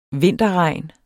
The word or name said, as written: vinterregn